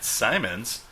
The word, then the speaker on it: Simonds: So what we did is we brought in our friend Matt Simonds.